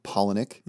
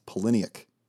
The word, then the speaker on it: Palahniuk: It's not Palahniuk. – Mm-hmm. – It's Palahniuk.